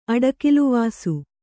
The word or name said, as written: ಅಡಿಕಿಲ್ವಾಸು